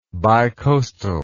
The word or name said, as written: bicoastalism